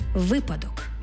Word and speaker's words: випадок: «роз» – наголос на префіксі. Випадок.